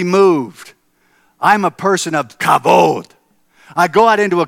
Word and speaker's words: kavod: I have what I describe as kavod. Say that with me. Kavod. Let me spell it. In C-A-V-O-D.